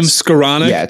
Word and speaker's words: Skowronek: Van Jefferson is that how you say his name? Skowronek? – Yeah.